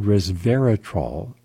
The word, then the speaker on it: resveratrol: Also, scientists at King's College London investigated resveratrol, three syllables, for its anti-hypertension properties.